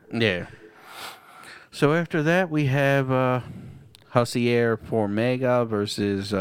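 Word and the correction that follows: Jussier: I think they actually say Juicier. – Juicier? – I think I mostly heard people pronounce that J.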